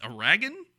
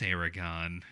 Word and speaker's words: Eragon: is it pronounced Aragon or Aragon? It's Eragon.